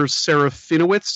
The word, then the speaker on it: Serafinowicz: which is Peter Serafinowicz. Did I pronounce his name right? He's in Guardians of the Galaxy. – That sounds right.